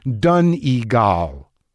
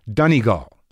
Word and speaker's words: Donegal: It's pronounced Donegal. Donegal. Gall. Donegal.